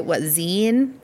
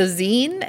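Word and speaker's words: Xeen: But yeah, it's a visual novel that was developed by Zine, is maybe how you pronounce it. It's X-E-E-N. – Yeah, I have no idea how to say that. – Yeah, so Zine, and published by Square.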